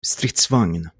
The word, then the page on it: Stridsvagn: [ˈstrɪ̂tːsvaŋn]; Swedish